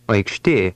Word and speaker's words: Aikštė: Aikštė. Tė. Tė. Aikš. Aikš. Aikštė. Aikštė. – Did you hear the ksh sound in the middle? It resembles the middle of the English word fixture.